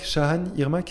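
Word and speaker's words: Çağan Irmak: Euh, et en numéro 30, My Father and My Son de Shagan Irmak. Shahan Irmak, c'est ça Ouais, on prononce pas le G en fait.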